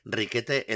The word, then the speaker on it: Riquet: En castellano se dice Riquete el del copete, pero en francés la palabra es Riquet, eh, que quizá es una Mm, abreviatura de Enriquet, ¿no?